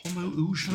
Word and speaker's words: Homoousion: Homoousion. – Homo what? How do you spell that? – H-O-M-O. – Yeah. – O-U-S-I-O-N. Homoousion.